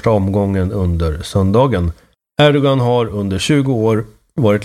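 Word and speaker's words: Erdoğan: Erdogan, det uttalas i Erdogan. Det har man lärt sig. Med det här special G-et är det kanske.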